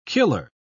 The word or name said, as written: killer